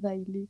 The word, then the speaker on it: Daily: Pero no, es, así no se dice mi nombre. Mi nombre es Daily, con un acento en la última I.